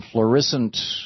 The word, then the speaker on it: Florissant: Gene in Florissant, Missouri. Am I saying that right, Gene? – Yes, sir, you are.